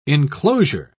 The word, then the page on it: inclosure: British English